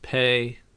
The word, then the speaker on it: Pei: Pay is pronounced pay. P-E-I is pronounced pay, in case you were wondering.